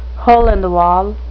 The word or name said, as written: hole-in-the-wall